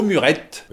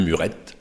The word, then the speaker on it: Muret: Bonjour à tous et bienvenue pour cette 32e étape qui va nous mener du barp au murette. Oui, parce qu'on m'a expliqué qu'il fallait prononcer le T, le murette.